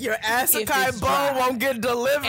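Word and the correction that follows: açaí: And Toya, for the 19th, 1,000th time, it is pronounced acai. It is an acai bowl. It is an acai bowl. An acai bowl. And you out here talking about an Asakai bowl on the internet.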